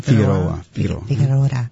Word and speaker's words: Figueroa: Yeah, they, as far as Omar Figueroa, how do you say his last name? – Figueroa. – I probably still said it wrong.